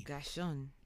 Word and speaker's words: Guychone: It's spelled E-D-O-U-A-R-D, Edouard. – Oh, Edouard. – G-U-Y-C-H-O-N-E. – Guyshon.